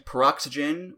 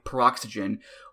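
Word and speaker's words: Peroxwhygen: And I guess you're supposed to say the word Y like it's the letter Y, Paroxygen, which is a very Jeff Hardy thing to do.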